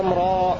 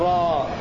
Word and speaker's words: راء: وهنا يعني نطق الراء النطق صحيح هكذا: ألف لام راء، لا نقول راء ولا نقول راء، نقول راء فقط بمقدار 2 حركات.